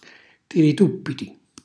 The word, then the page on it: tirituppiti: Calabrese